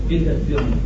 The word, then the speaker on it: Spiegazione: Spaghetti on. What is it? How do you say it? I don't. How do you say it? Purport. – Spiegazione. – Spiegazione. – Spiegazione. – Huh? Spiegazione. – Spiegazione. Spiegazione. – That's what I said.